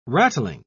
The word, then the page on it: rattling: British English